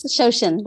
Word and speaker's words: Shoshin: but I think it's Shoshin. – Yes, Shoshin.